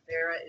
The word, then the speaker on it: Vera: Vera is V-E-R-A, and pronounced Vera like Sarah.